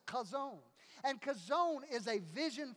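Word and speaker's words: Kazon (חזון): Kazon is one of those Hebrew words that is interesting because to truly say it right, you have to say it almost like you're clearing your throat. Kazon.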